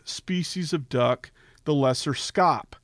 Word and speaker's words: scaup: So those are lesser scops grunting. If you've never heard the name scop, it's spelled S-C-A-U-P.